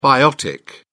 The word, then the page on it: biotic: British English